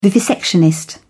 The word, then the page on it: vivisectionist: British English